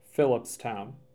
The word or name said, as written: Phillipstown